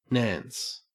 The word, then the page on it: nance: Australian English